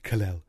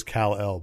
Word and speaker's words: Kal-El: And Marlon Brando also forever changed the pronunciation of Kal-El. Everybody was like, Kal-El, my little Kal-El. Everybody else says Kal-El, but no, Marlon Brando refused.